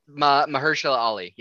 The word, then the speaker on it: Mahershala Ali: Mishan Mali, I can't say it correctly. – Mahershala Ali, yeah.